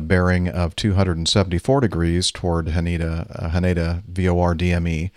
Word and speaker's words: Haneda: Am I saying that right? Haneda? Haneda? – Haneda, yes.